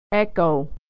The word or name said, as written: echo